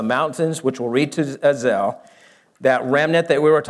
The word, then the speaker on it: Azel: Now, this valley is called Azel, A-Z-E-L. Sometimes it's spelled A-Z-A-L.